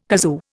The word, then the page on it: kazoo: Indian